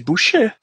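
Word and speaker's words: Bouchette: você que é um rapaz estudado, por favor, elucide para mim como é que é a pronúncia. Ed Boucher. – Pronto. Boa. Sim.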